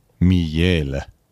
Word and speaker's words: miele: Miele wird es geschrieben, ausgesprochen wird es natürlich Miele.